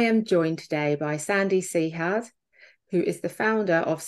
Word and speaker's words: Sehhat: So the organization is called Sehhat.